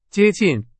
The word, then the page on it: 接近: Chinese